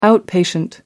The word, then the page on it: outpatient: American English